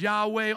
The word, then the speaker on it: Yahweh: Now run that mouse over the Lord there and you'll see the divine name of God, Yohevah in Hebrew, pronounced as Yahweh, also Jehovah.